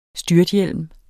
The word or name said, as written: styrthjelm